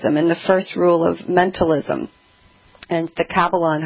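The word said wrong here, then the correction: Kybalion: how you had came, is it, am I saying that right, the Kabbalon? – Actually, the Kabbalion.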